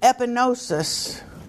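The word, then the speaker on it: epignosis: You see, the G is not pronounced. That's a help. Look at a word we use today, and it'll tell you how to pronounce it in Greek. The G is not pronounced. Epinosis, to know.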